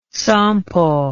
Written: Sampo

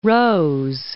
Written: Rose, Mount

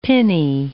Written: pinnae

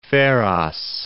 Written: Pharos

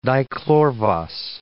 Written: dichlorvos

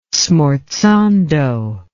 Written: smorzando

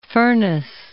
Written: Furness

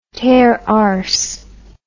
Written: teararse

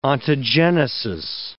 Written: ontogenesis

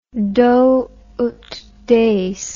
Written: do ut des

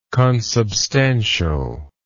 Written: consubstantial

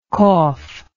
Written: cougher

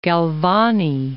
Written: Galvani

